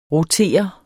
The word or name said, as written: rotere